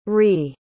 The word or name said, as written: re